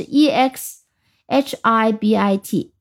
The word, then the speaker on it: exhibit: 那么这个 exhibit 的拼写非常要注意的是，它里面加了个 h，这个 h 是不发音的，加在 e x 后面，h i b i t，e x h i b i t。